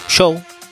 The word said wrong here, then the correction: Joe: Ah, Joe. El viernes estuvo Joe. Pronuncia bien, por favor. – El y pronuncia bien. Show, no. Joe. – Se dice Joe.